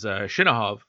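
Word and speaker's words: Chinakhov: Looks like Chinakov. You heard Slava pronounce it as Shinohov.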